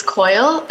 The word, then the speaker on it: Coyle: My name is Erica Aites Coyle, and for the record, the last name is spelled C-O-Y-L-E.